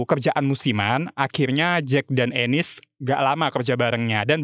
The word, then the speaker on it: Ennis: Oke, okay, pronunciation-nya benar itu Ennis. Agak-agak kayak kalau orang berbahasa Inggris ngomong anus kalau gue denger-denger sih. Karena agak nggak nyaman, gue pronounce nama dia Enis aja ya.